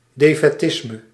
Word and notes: defaitisme: /defɛˈtɪzmə/

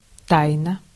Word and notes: тайна: /ˈtajnə/; taina

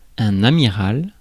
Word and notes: amiral: [a.mi.ʁal]; France

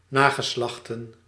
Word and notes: descendance: /de.sɑ̃.dɑ̃s/

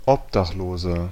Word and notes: Obdachloser: [ˈʔɔpdaχˌloːzɐ]

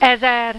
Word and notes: ezer: /ˈɛzɛr/

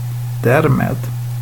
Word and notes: thus: /ˈðʌs/; American English

